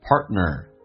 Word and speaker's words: partner: 那么下面一个单词很多人听的也许不太习惯，那么在口语化当中 partner 那个 t 通常是不发音的，那么到这边气流就阻塞一下，就变成 partner，所以就变成 to your partner，所以这个对听力有很大的一个影响。